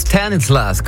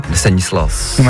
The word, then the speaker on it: Stanislas: On a des nuages sur la place Stanislas, comme on vient d'entendre. Exceptionnel l'accent anglais quand exceptionnel. – On prononce Stanislas.